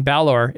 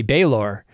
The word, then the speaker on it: Balor: Apparently, I've been mispronouncing Balor. It should be Baylor all this time.